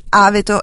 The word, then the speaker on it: Aveto: E poi saluto di nuovo Ernesto che dice che Aveto si dice con l'accento sulla A e non Aveto, quindi Aveto,